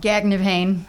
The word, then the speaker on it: Gagnepain: Gagnepain.